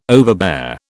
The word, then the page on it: overbear: British English